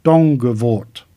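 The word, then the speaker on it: Donkervoort: Saying the name is slightly quicker, with it's pronounced simply as Dongevoort, a favourite of mine.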